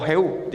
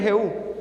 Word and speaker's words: Jehú: o Jehú, lleva el acento en la O, viva el rey Jehú.